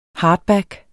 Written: hardback

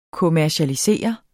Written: kommercialisere

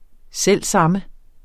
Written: samme

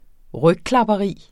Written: rygklapperi

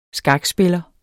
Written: skakspiller

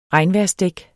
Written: regnvejrsdæk

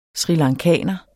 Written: srilankaner

